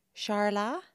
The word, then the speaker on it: Charla: Now, it's spelt Charla, but I'm thinking it's... – Charlie. – Charla? – Charla. – Like a soft ch.